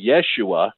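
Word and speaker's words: Yeshua (ישוע): So Jesus is the more English pronunciation of it, but it's actually Yeshua in Aramaic, which sounds a lot like Joshua from the Old Testament, because those two names come from the same word family.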